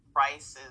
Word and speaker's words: Pryce: Price is my last name. It's spelled P-R-Y-C-E on purpose.